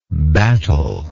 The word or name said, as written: battel